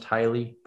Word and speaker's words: Hyle: I think it's pronounced Hyle, but it's the H-Y-L-E.